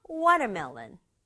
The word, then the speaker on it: watermelon: 앞에 water 있고 melon 붙어서 앞부분에 강조 줘서 watermelon.